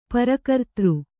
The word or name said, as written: ಪರಕರ್ತೃ